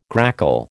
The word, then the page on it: grackle: American English